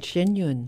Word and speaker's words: Shen Yun: And for the next hour, we'll be talking about Shen Yun. Did I say that right? I know I messed it up last year. – No, you got it right. Shen Yun.